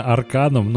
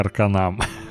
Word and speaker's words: Arcanum: Я уверен, что правильно именно Арканом, но просто, знаешь, в детстве, в моем сосничестве, так сказать, когда мне друг поделился игрой, английский язык мы, конечно же, нифига не знали, такие, ну, наверное, это читается как Арканам.